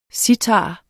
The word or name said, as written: citarer